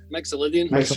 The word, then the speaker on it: Mixolydian: And it is Sweet Home Alabama in the key of G major or D Mixolydian? Is that how you say that? – Mixolydian, I think. Yes, yes. – Is that right? – It's a diminished. – How do you say that? – Mixolydian.